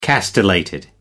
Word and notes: castellated: American English

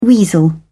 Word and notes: weasel: British English

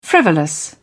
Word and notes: frivolous: British English

